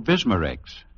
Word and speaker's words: Bismarex: So for relief that's prolonged as well as fast, get Rexall Bismarex. That's spelled Bisma-Rex, Bismarex.